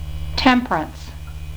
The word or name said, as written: temperance